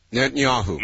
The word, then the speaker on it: Netanyahu: Yehantata, I still can't pronounce his name, could you give it to me? – Netanyahu.